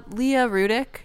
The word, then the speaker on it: Leah Rudick: Leah Rudick, just my name, or my website.